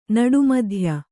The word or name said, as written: ನಡುಮಧ್ಯೆ